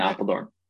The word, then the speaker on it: Apeldoorn: So the CrossFit Lowlands Throwdowns are going to be in Apeldoorn. Is that if I say that correctly? So it's in Yeah, it's in Apeldoorn.